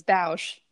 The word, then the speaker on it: Bausch: it actually rhymes. It's Bausch.